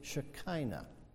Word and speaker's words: shekinah: The Greek word for dwelling was skino, a translation of a Hebrew word, shakan, a word that many are more familiar with in its anglicized pronunciation, shekinah.